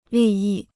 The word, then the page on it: 利益: lì yì